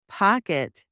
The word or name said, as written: pocket